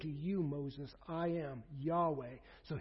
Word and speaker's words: Yahweh: That is the word we pronounce, Yahweh.